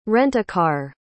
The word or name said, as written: rent-a-car